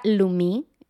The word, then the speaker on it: Lumie: Es de la marca Lumi, se escribe Lumie, y lo compré en Amazon, ¿ok?